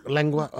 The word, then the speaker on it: lengua: How do I pronounce it? Lengua? Lengua.